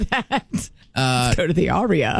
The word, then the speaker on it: Aria: Oh, how do you pronounce A-R-I-A? – Aria? – Aria.